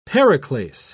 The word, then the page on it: periclase: British English